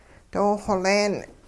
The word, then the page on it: doo-xoleen: hupa